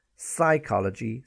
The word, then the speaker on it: psychology: Yeah, psychology, psychiatry, psyche, silent P, all of the time.